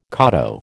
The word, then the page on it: koto: American English